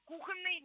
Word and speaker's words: кухонный: Но нет, слово «кухонный» не амограф, и в любом значении у этого слова должно ударение падать на первый слог.